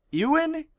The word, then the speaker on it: Ewan: Ewan? E-W-A-N. Ewan? – Yeah, like Ewan McGregor.